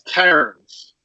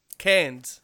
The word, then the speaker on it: Cairns: That he was in a place in Queensland called C-A-I-R-N-S, Cairns. – Cairns.